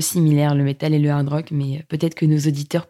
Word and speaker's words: hard rock: Mais effectivement, c'était un festival français, c'est ça, et c'était du rock, hein, du hard rock, non Oui, alors il y a du hard rock, comme on prononce à la française euh, sans prononcer le H.